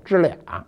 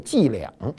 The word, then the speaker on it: 伎俩: 我们小时候老说一句话，特有意思，就是说呢，说说这人啊，净跟我玩这小支俩。什么叫支俩呢？标准发音应该是这样的，就是他老跟我玩这小伎俩。